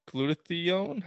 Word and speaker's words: glutathione: Well, you had this one on glutathione.